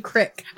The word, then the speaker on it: creek: And I sat next to a creek. I mean crick. – A crick? – I said it right the first time.